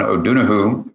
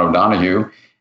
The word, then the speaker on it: O'Donohue: I used to do work with a Catholic priest who was also an Irish mystic named John O'Donohue, or we pronounce it in America, John O'Donohue.